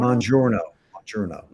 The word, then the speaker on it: Mounjaro: Mounjaro.